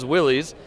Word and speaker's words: Willys: Yeah, that's right, because that's the proper way to say it. And everybody says Willys, which is like, but it's Willys is the proper way. – Right. So from now on, I'm going to say Willys. – We are speaking to a true Jeep aficionado. Because he said Willys the proper way. I'm just throwing it out there. – Yeah. Now, why is it Willys? Because that's how the man pronounced his name.